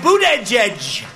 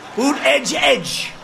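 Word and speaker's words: Buttigieg: We have a young man, Boot Edge Edge. Boot Edge Edge.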